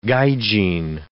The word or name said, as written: gaijin